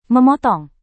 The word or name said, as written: memotong